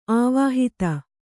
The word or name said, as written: ಅವಹಿತ